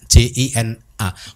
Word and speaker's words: jina: Buddha itu mempunyai julukan jina, J I N A. Bukan bukan bahasa Indonesia loh ya. J I N A, tapi memang bacanya jina gitu.